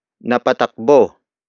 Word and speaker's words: napatakbo: Word for word, it's napatakbo siya dahil dito. Napatakbo siya dahil dito. Napatakbo siya dahil, dito. The first word is napatakbo. Napatakbo. Napatakbo. Napatakbo is spelled as N-A-P-A-T-A-K-B-O.